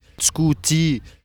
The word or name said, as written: Scouti